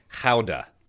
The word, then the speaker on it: Gouda: I'm going to mispronounce it, but in Netherlands you say Gouda.